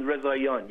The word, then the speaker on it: Rezaian: Jason Rezaian, yes.